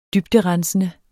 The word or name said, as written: dybderensende